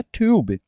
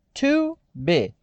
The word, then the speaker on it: tube: Uma coisa que eu tenho que dizer para vocês com referência à palavra tube, ela é uma palavra monossilábica, ou seja, ela só tem uma sílaba. Sim, assustou? Se nós olharmos do nosso, da nossa perspectiva de língua portuguesa como brasileiros, nós entenderíamos que tube ela é de sílaba. To be, T-U-B-E, to be.